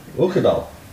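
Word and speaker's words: Uigeadail: Okadal.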